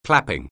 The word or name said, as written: clapping